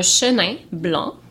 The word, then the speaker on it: Chenin Blanc: Okay, so we are drinking a Chemin Blanc. We are drinking a Chenin Blanc 2014.